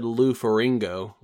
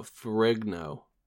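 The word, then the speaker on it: Ferrigno: You also said Lou Faringo, like flamingo, like rhyming with flamingo, but Faringo. Lou Faringo. No, Frigno. G is before the N, obviously.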